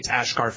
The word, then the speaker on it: Asghar: and actually you say the S is an SH, so it's Asghar Farhadi is how you say his name.